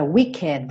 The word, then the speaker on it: wicked: É o adjetivo wicked e se pronuncia. Pode caprichar no é wicked, wicked, tá? Não precisa falar wicked. O E existe, tem som.